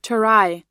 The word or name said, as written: terai